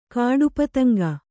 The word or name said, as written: ಕಾಡುಪತಂಗ